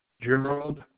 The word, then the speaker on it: Gerald: Gerald. – Can you spell it? – G-E-R-A-L-D.